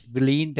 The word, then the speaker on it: Vileen: My name is Bileen, that is spelled V as in Victor, I-L-E-E-N, and last name is Shah, S-H-A-H.